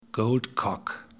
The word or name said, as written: Gold Cock